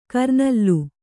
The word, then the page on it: ಕರ್ನಲ್ಲು: kannada